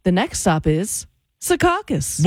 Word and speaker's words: Secaucus: Okay, but what's the right way to pronounce it? – Hudson County people probably say Secaucus. – It is. – I'm from Hudson County originally. – Yep, exactly. It's Secaucus, but a lot of people say Secaucus.